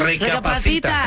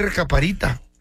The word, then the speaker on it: recapacita: Recapacita. – Te iba a decir recaparita. Recapacita.